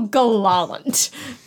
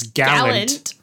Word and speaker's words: gallant: And Fulton responds with how gallant, but irrelevant. – Yeah, that's not how you pronounce that word. It's usually it's gallant.